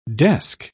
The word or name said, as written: desk